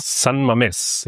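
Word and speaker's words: San Mamés: Frage wäre das erste, die Kathedrale, also das Stadion San Mames, wenn ich es dann hoffentlich so richtig ausgesprochen habe. – San Mames, ja.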